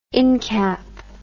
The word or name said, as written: incap